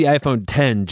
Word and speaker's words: iPhone X: I'm here, desperately seeking an iPhone X, hey? – It's pronounced the iPhone 10, Joel.